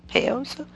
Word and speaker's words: Paizo: or paizo, paizo. Anyway. – Oh, Paizo Publishing. – Paizo, P-A-I-Z-O.